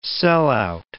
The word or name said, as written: sellout